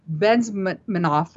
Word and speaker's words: Bezmenov: So according to former Soviet KGB propagandist Yuri Bezmenov, I guess that's how you pronounce Bezmenov.